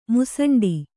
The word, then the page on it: ಮೂಸುಂಡಿ: mūsuṇḍi